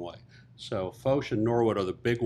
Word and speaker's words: Foch: This is how you pronounce Foch.